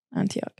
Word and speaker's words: Antioch: Antioch. – Okay, wait, hold on. Start from the top. Paul opposes Cephas. When Cephas came to Antioch, I opposed him to his face because he stood condemned.